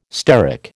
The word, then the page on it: steric: American English